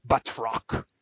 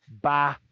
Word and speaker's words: Batroc: Yeah, I mean, yeah, I always say Batroc, but like they were pronouncing it like the proper French way. Batroc. – I'm it's American. Not, we don't do that. – It's not Batroc. It's Batroc. Rock.